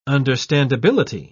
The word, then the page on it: understandability: British English